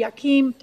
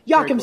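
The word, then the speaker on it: Yakym: Congressman Rudy Yakim. Did I say that right, Rudy? – Yakim, sorry, Rudy Yakim, Congressman Mark Mesmer, and Aaron.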